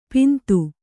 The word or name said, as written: ಪಿಂದು